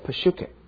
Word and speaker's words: psyche: 当然在希腊文里面哈，我们这边所用的所写的所有的所谓血气这个字哈，它的希腊文叫做 psyche， psyche，那么英文的念法呢，通常 p 是不发音的，所以就变成像 psychology， psychist 啊等等，就 psych 这个字的字头，你去查字典的话，这一排的字很多的，都是跟心理学啦什么这一类有关的。